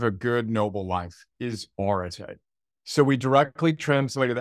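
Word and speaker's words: orate (ἀρετή): To step back, so orate is how you pronounce that funny-looking Greek word on my t-shirt and on the book,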